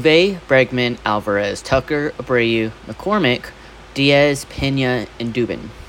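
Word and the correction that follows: Dubón: Dubon? Dubon. I always say his last name wrong.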